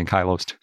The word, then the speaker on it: ankylose: I always want to say ankylosed, are probably male, end quote. As a side fun fact, ankylose is a verb, and it's pronounced ankylose, sort of like the alternate pronunciation for ankylosaurus.